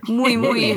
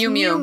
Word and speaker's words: Miu Miu: Mooey Mooey. Mooey fashion. – Mooey Mooey. Yeah, Mew. We're looking up the pronunciation. – It's, it's Miu Miu. Mew okay. Mew.